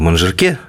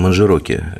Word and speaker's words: Манжерок: Но ну вот на, Алтае я увидел э, в Манжироке, я почему то думал, что надо в Манжирке, а оказывается в Манжироке, э, правильно говорят местные, в Манжироке я увидел, вот, собственно, как должен выглядеть хороший, да, может, даже не а нормальный русский туризм.